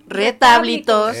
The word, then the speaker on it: retablitos: Los retablitos. Eso. Con acento en la A. – En la A. Retablitos. Ya, caballero, ponga usted el acento.